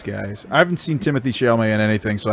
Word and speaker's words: Chalamet: For Milo, I cast Timothy Calumet. – Chalamet, I'm pretty sure.